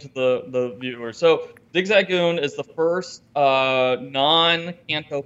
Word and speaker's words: Zigzagoon: So, Zigzagoon.